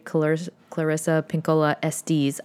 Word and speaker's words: Clarissa Pinkola Estés: This is another tool from the great Clarissa Pinkola Estés. I just learned that that's how you say her name.